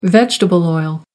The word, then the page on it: vegetable oil: American English